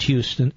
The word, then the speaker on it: Houston: He entered the bike path at a street called Houston Street, pronounced Houston, spelled Houston, but it's Houston Street.